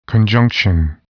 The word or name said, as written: conjunction